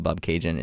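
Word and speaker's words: Bobcaygeon: But the reason why this song is called Bob Cajun is because frontman of The Tragically Hip, Gord Downie, thought that it rhymes with constellation, the word constellation.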